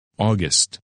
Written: August

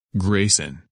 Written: Grayson

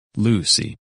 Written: Lucy

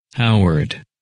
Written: Howard

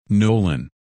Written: Nolan

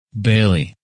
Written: Bailey

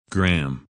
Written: Graham

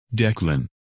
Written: Declan

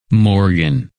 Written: Morgan

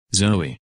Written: Zoey

